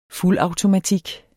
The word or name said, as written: fuldautomatik